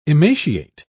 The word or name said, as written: emaciate